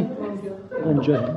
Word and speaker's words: Gonja: Is that how you pronounce it? Gonja. Yeah.